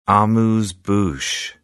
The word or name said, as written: amuse-bouche